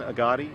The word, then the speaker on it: Agati: Yes, the name is Ben Agati. It's A-G-A-T-I, Assistant Attorney General at the New Hampshire Attorney General's Office.